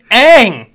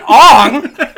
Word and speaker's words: Aang: It's Aang, not Ong.